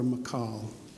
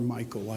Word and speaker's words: Michal: I call her Michal. You might call her Michael. I don't know how you pronounce it. But I don't like a girl being called Michael. And the Hebrew pronunciation is Michal, so I go with Michal.